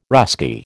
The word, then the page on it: Russki: American English